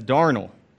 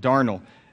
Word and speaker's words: darnel: And if you look over on the notes, it says darnel. As far as I know, that's how you pronounce it, darnel.